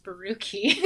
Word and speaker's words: Borucki: We've always called us Baruki until someone from the Eastern Bloc told me that it's actually pronounced Baruki.